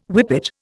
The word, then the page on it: whippet: Indian